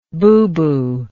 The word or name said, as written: bubu